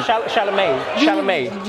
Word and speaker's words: Chalamet: You want Mark Wahlberg riding around the hood with Timothy Chalamet? How you say his name? – Chalamet.